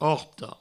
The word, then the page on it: perorta: Provençal; perorto